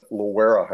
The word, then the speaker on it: Loara: The first place was at Loara High School. It's spelled L-O-A-R-A.